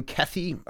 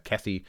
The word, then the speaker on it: Kethe: Yeah, so William Kethe, I believe that's how you pronounce the last name, K-E-T-H-E, is the author.